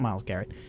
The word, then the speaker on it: Myles Garrett: Yeah, I mean, we remember how uh, how serious everyone got about the Myles Garrett situation when he used his uh, uh, Myles Garrett. Did I get that name wrong? – Yeah, Myles Garrett.